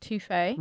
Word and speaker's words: Tofe: They're called Toffee, but they're spelled T-O-F-E, but it's pronounced Toffee.